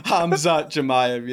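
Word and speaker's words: Khamzat Chimaev: You have Robert Whittaker, you have DDP, now you have Sean Strickland, you have Paulo Costa, you have correct me if I'm saying this name wrong, Hazmat Machaiva. Am I saying that right or incorrect? – Did you just try to say Hamza Chimaev? – Yeah.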